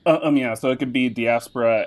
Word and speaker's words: diaspora: He'll be specializing in African American and African diaspora.